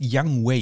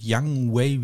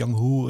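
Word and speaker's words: Younghoe: Ähm, Yang Wei Ko, der heißt Young Wei übrigens. Also das wird Young Wei wie, wie quasi wie der junge Weg aus, ausgesprochen. Das wird nicht Young Hu oder irgendwie sowas. Young Wei Ko,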